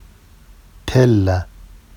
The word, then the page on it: tella: Vafsi